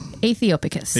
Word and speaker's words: Aethiopicus: Aethiopicus. – Aethiopicus. Mm-hmm. It's the A-E sound, mm-hmm. Or I guess combination of vowels. – Yeah. – T-H-I-O and then picus, P-I-C-U-S. – Yeah. Mm-hmm. – Aethiopicus.